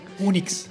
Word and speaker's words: UNICS: Unix con C, ¿cómo es ese? – U-N-I-C-S. – Ajá, Unix.